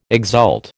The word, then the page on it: exult: American English